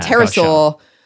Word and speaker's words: pterosaur: Spelled with a P, yes. – Kind of terror. – Yeah, so yeah, pterosaur.